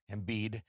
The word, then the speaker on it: Embiid: Embiid.